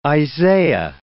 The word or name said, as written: Isaiah